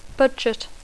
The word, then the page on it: budget: English